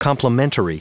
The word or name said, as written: complementary